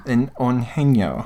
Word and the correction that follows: Eugenio: No, it's E-U. Eugenio.